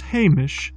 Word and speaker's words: haimish: The word is Hamish. That's spelled H-A-I-M-I-S-H.